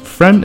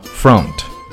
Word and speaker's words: front: Front and Front 前面这个词呢注意不要读作 front Not front It's front 注意这里是没有后鼻音的